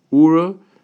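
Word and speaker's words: Oorah: So I offer to the two of you, to everybody here, these two organizations, Ura and Partners in Torah.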